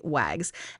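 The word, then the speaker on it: WAGGGS: It's the abbreviation, but said phonetically, WAGS. And it stands for the World Association of Girl Guides and Girl Scouts. So it's WAGS with three G's.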